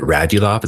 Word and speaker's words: Radulov: I think that while he's good, especially if we get somebody like, is it Radulov? Is that how you say his name? – Yeah.